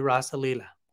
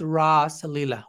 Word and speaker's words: Rasa: It was Rasa Lila, but not Rasa Lila with a long A, as generalists define the Rasa Lila.